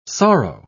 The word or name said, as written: sorrow